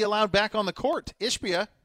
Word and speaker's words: Ishbia: And then the owner, how do you pronounce it? Ishbia? – Ishbia, yeah.